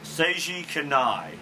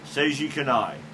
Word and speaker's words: Seiji Kanai: It's spelled S-E-I-J-I, then K-A-N-A-I. I think it's... I've heard people pronounce it on how to play videos, but I'm not sure I remember right. But I think it's Seiji Kanai.